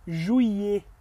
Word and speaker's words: juillet: juillet, juillet, juillet, A gente não pronuncia esses L's aí não, tá bom? juillet, juillet, Nem o T no final.